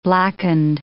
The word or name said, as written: blackened